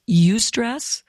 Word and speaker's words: eustress: It's not a word I've ever used in clinical practice, but it's called eustress, and it's spelled E-U, stress. You say you.